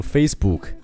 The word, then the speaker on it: Facebook: 你可千万要注意，不要读成 Facebook，书不读 book，而是 book，中间的元音是发一个呃呃这样子，很放松的一个音，Facebook。